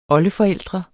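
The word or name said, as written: OLDEFORÆLDRENE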